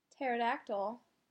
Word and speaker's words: pterodactyl: Why can't you hear a pterodactyl go to the bathroom? – Why not? – Because the P is silent.